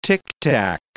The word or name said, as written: tictac